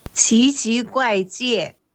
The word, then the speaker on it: 奇奇怪界: pues bueno, le dije que me grabara como se dice y lo vamos a poner aquí para que veáis que no se parece en nada a cómo se pronuncia en japonés. – 奇奇怪界. – O sea que ya veis que no, tiene nada que ver con Kiki Kai ¿no?